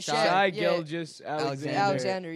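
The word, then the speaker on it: Shai Gilgeous-Alexander: Shai Gilgeous Alexander.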